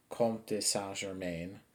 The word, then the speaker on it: Comte de Saint-Germain: So the actual person, like how most of these vampires are depicted when they were alive, was Count of Saint-Germain, or in the French pronunciation, it's Comte de Saint-Germain, while he was in France.